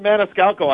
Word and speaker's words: Maniscalco: Sebastian Maniscalco.